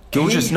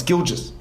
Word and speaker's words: Gilgeous: Gilgeous? No, it's Gilgeous. It's just Gilgeous Alexander.